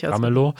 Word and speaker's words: Ramelow: Bodo Ramelow, mhm. heißt der, spricht man den so aus? Ja, richtig. Ramelow.